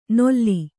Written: ನೊಲ್ಲಿ

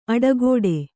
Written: ಅಡೆಗಟ್ಟು

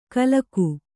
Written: ಕಲಕು

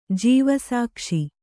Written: ಜೀವಸಾಕ್ಷಿ

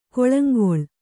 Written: ಗೋಳಾಂಗೂಳ

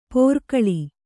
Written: ಪೋರ್ಕಳಿ

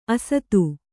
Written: ಅಸಾಧು

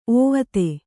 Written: ಓವತೆ

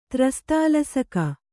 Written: ತ್ರಸ್ತಾಲಸಕ

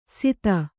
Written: ಸಿತ

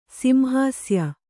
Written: ಸಿಂಹಾಸ್ಯ

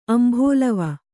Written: ಅಂಭೋಲವ

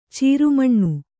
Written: ಚಾರ್ಮಣ

